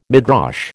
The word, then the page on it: Midrash: American English